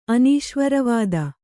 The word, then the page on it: ಅನೀಶ್ವರವಾದ: anīśvaravāda